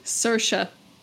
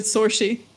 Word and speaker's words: Saoirse: Did I say her name wrong? – Very. Saoirse. – What did I thought I said Saoirse. – Nope. You said Saoirse.